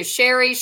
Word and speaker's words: Sheri: Like I said, I thought it was Johnny, but we, you know, it's like people mess up my name too. Sheri, Cherie, Cherry, like, you know, who knows?